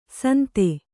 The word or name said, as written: ಸುಂದು